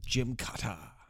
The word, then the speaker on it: Gymkata: It's G-Y-M, as in the the gym. Then kata, K-A-T-A. It's Gymkata.